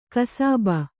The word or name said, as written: ಕುಸುಬು